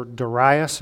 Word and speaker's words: Darius: A couple chapters later in Daniel, King Darius, or Darius as some pronounce it, he was in that second great kingdom as the world knew it.